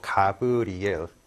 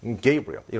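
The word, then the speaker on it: Gabriel: 역으로 이제 우리가 이제 외국인을 부를 때도 뭐 가브리엘 물론 발음적으로 게이브리엘이겠지만 우리는 보통 이제 강세 없이 가브리엘 하면 걔네들 입장에서는 똑같이 어색하게 들리거든요. 네, 게이브리엘 이름 전에 강세를 들어가서 게이브리엘 이런 식으로써 그거를 흉내를 내는 거예요.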